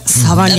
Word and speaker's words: Sauvignon: Cabernet Sauvignon. Is that how you say that? Sauvignon. – That's how you say it.